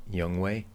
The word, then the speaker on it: Younghoe: Younghoe Koo.